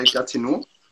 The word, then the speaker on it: Gatineau: Damals in Kanada warst du 2 Jahre bei Gatineau. Ich hoffe, das habe ich richtig ausgesprochen. – Ja.